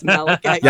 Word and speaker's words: Malachite: Oh, and I'm telling Tales of Tamriel, that's how you pronounce it as well. It's Malachite.